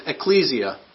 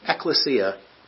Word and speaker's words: ekklesia: The Greek word used in Matthew 16 is ecclesia, or ekklesia, depending on how you like to pronounce it.